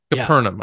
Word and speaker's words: Capernaum: Capernaum. – Yeah. Capernaum. And I asked, because Barry says Capernaum. – Yeah, I've heard it said so many different ways. – They would tell me Capernaum. – Capernaum.